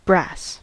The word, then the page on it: brass: American English